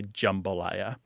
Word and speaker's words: jambalaya: And that said, if you go to cooking shows on YouTube and look up recipes for jambalaya or jambalaya, you will find a staggering, probably 99 out of 100 people say jambalaya.